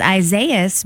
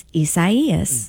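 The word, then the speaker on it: Isaías: Well, because again, yesterday I thought it was Isaias because it looks like the biblical Isaiah. Mm-hmm. And so I thought, Isaias, but instead, apparently, it's a Hispanic name, which means that it's pronounced differently, and it's Isaias.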